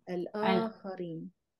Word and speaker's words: الآخرين: Pronounce the مادة here. – أخرين. – So مادة is not a. Hamza is مادة. You see أخرم. – this? Yeah. – يا. – الآخرين.